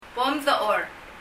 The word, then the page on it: bom dechor: bɔm ðə ? ɔ:r